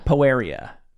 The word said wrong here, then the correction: Poerio: Okay. – Is how somebody who interviewed her pronounced it. Poeria. – Julia Poeria. – Poerio, rather. I'm glad.